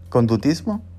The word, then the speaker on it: conductismo: En psicología, este principio se aplica en diferentes teorías que buscan explicar el conocimiento humano, como el conductor conductismo. ¿Lo dije bien? ¿Conductismo? Ah, ya, conductismo.